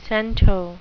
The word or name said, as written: cento